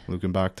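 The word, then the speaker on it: Luckenbach: That's not how you say it. It's like Lukenberg, Texas, but there's no ER or something. – Lukenbach, Texas.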